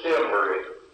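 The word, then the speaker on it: Demory: My name is Cluis Demery, C-L-E-W-I-S-D-E-M-O-R-Y.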